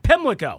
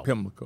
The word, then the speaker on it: Pimlico: At Pimlico. – Pimlico.